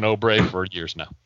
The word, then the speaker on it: Oubre: We had an Obre from Ken instead of Oubre. Pretty similar to Ombre, though.